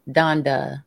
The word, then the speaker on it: Donda: What is it? Donda or is it Dondra? – Donda. – Donda.